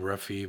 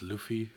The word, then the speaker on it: Luffy: Well, I think we call him Ruffy, but it's pronounced Luffy, I guess.